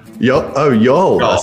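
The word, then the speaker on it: Youghal: Mate, how are you doing in, is it Yogul? Yogul? How do I pronounce it? No, it's Y'all. – Y'all. A silent.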